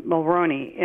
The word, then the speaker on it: Mulroney: It was signed on to by your Prime Minister, Brian Mulroney, and I guess I'm saying his name right. I don't know. – Yes, you are.